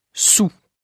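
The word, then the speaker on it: soûl: Attention, certains mots ont une orthographe et une prononciation complètement différentes. – Il a trop bu, il est sous. – On prononce sous.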